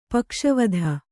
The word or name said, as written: ಪಕ್ಷವಧ